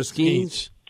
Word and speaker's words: Skenes: Is it Skenes or Skenes? – Skenes.